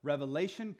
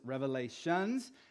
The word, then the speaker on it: Revelation: The book of Revelation, commonly mispronounced as Revelations, in which John records a vision given to him by God that would happen in the end time.